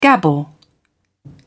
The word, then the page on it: gabble: British English